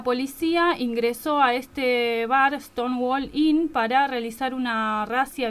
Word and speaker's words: Stonewall: Stonewall, ¿no?